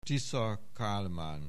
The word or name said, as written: Tisza Kálmán